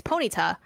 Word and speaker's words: Ponyta: It's Ponita,